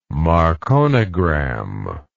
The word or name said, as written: marconigram